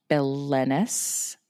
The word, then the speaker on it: Belenus: So Beltane is probably named after the Celtic sun god Belenus, I think is how you say it. It's B-E-L-E-N-U-S.